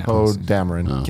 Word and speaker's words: Poe Dameron: Poe Dameron. Was that how you pronounce it? – Poe Dameron.